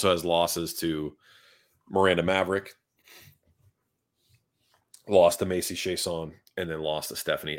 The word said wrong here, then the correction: Chasson: Stephanie Egger's pretty good, and Macy Chason, or Chasson, sorry. I still want to pronounce her name correctly, but it's Chasson, whatever.